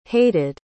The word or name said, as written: hated